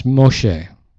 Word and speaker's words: Moshe: The Hebrew pronunciation of that Egyptian name is Moshe. M-O-S-H-E-H.